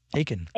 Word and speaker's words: Achan: Is it Achan? – Yeah, that's right. Achan. Achan, like belly Achan.